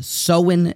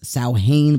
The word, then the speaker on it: Samhain: that the ancient Celtic festival of the Samhain nation, I thought it was Samhain, but apparently it's pronounced Samhain, where they would have this sort of festival where they would light bonfires and wear animal skins.